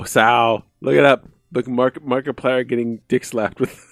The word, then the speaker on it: Markiplier: that it was Markiplier. I didn't know that was how you pronounced it. – Markiplier. Yeah, like multiplier.